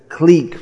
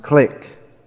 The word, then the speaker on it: clique: And this way there's a clique formed, which is sometimes mispronounced clique. Another French word. Elite is also a French word.